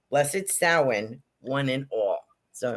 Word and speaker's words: Samhain: S-A-M-H-A-I-N, we would say Samhain, is actually Samhain. It's pronounced Samhain, S-O-W-I-N. So that's how it's pronounced in the Celtic world.